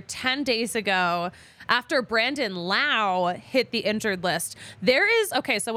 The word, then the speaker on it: Lowe: Because they're spelled the same way, but they're pronounced differently. They remember Brandon Lau because it rhymes with ow, and Brandon Lau is injured more.